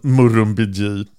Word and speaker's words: Murrumbidgee: Och jag fick en uttalsanvisning. Murrumbidgee, ja.